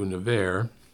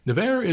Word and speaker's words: Nevers: Nevers is, uh, it's uh, not, it's, How do you spell it? N-E? but it's uh, never, uh, never with an S in the end.